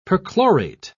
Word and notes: perchlorate: British English

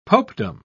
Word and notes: popedom: British English

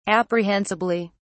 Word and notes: apprehensibly: British English

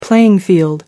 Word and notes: playing field: American English

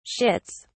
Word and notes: shits: British English

